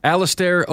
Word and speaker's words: Alistair: Aster Overeem. – Alistair Overeem.